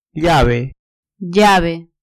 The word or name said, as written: llave